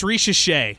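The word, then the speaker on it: Risacher: I don't even know how to pronounce Zachary Saker. I might be pronouncing it wrong. – So it's Richichet. It's Richichet. Yeah. Zachary Richichet.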